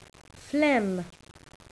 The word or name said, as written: phlegm